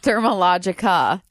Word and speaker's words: Dermalogica: Is Dermalogica, how do you say it? – Dermalogica.